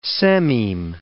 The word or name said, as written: sememe